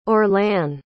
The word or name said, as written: orlan